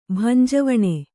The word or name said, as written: ಭಂಜವಣೆ